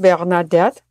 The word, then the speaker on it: Bernadette: Hi, my name is Yayuk Inupiaktun, and I do have an English name that has a very beautiful French pronunciation, Bernadette, otherwise known as Bernadette Alvanestimpfel in English.